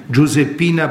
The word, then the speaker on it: Giuseppina: Heilig gesprochene Afrikanerin Giuseppina Bakita. Also Josefine Bakita, Giuseppina ist italienische Aussprache.